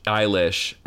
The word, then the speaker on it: Eilish: tag Billie Eyelash, Eilish, and tell me how bad I pronounced her last name.